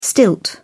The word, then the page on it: stilt: British English